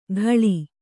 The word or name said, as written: ಘಳಿ